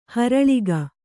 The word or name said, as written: ಹೊರಲಿಗ